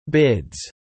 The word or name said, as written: bids